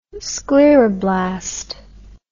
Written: scleroblast

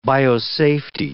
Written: biosafety

